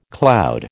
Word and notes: cloud: American English

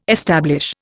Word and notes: establish: Indian

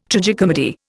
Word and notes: tragicomedy: Indian

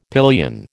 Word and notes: pillion: American English

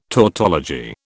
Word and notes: tautology: British English

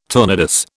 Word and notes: tournedos: British English

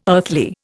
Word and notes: earthly: Indian

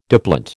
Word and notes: diplont: American English

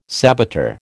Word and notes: saboteur: American English

